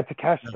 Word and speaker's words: Takeshita: Yeah, Takeshita.